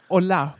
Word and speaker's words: Hola: Das heißt dann aber Hola, weil das H ist stumm und die Betonung liegt auf der zweiten Silbe.